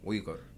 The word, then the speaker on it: Uyghur: Uyghur. – Yeah, so it's like there's a ra sound. It's like a G, it's spelled U Y G H U R. So the G H makes a ra noise.